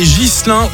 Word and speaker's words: Ghislain: On fêtera les Ghislain ou les Guilain, ça dépend de la personne, j'ai l'impression, la prononciation n'est jamais sûre.